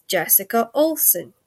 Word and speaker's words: Jessica Olson: And now it is my distinct and absolute unbelievable pleasure to introduce to you Jessica Olson.